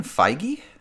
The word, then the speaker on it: Feige: I'm going to go with, and tell me if I'm pronouncing this wrong, but Kevin Feige? – Oh, yes.